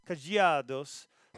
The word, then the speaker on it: Cadeados: Cadeados. So that's a better pronunciation there in Portuguese than I'm producing.